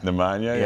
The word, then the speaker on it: Nemanja: It was Nemanja. A hard J the first time. I didn't mean to do that. Strahinja and Nemanja? – Nemanja, yeah.